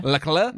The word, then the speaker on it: Charles Leclerc: Charles Leclerc. – Leclerc? – Yeah.